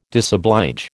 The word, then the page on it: disoblige: American English